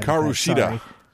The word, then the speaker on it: Hikaru Shida: We have Penelope Ford uh, taking on Herkua. Oh God, I'm so fucking bad. – Hikaru Shida. – Hikaru Shida. That's how you say it because I barely speak English.